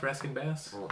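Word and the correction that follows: Rankin-Bass: Rankin Bass. – Rankin Bass. I always get confused about that.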